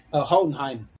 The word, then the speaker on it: Hohenheim: Hohenheim. – Second H is silent.